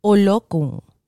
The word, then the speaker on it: Olokun: Olokun. Olokun. In Spanish, you're going to hear it two ways. Olokun or olokun, like the emphasis on the U.